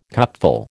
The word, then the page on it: cupful: American English